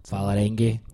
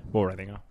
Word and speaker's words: Vålerenga: How do you pronounce it? – Vålerenga. – Vålerenga. From Oslo, you say Vålerenga.